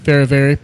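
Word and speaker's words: Fehérváry: Martin for, how do you say his last name? – Ferravari.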